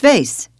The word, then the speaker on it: vase: The word spelled V-A-S-E, it's pronounced vase in the States, but vase in Britain.